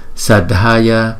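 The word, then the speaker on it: sādhaya: Sadhaya Sadhaya. Thấy không? Dha D H là dha dha dha.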